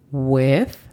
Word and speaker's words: with: With significa con. La pronunciación fonética de esta es U-E-T-H. With. Recordando que para hacer la pronunciación de la TH, ponemos nuestra lengua entre los dientes y soplamos with.